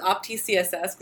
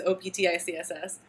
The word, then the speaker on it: OptiCSS: So optics, despite the fact that its name makes you think it should be pronounced as opti CSS,